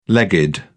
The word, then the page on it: legged: British English